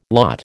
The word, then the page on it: lot: American English